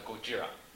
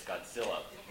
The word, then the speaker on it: Gojira: One of the things that My mom loves Gojira, mispronounced in English as Godzilla movies.